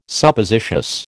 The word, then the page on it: Suppositious: American English